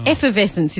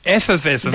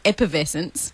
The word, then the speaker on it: effervescence: It would be if that's how you said the word. Oh. Effervescence is how you say it. But yeah, close. Good guess. – So I got the word right, but I got it wrong. – Yeah, you've got the word right, but you've been mispronouncing it wrong your whole life. – So it's effervescence. – That's right, not epervescence.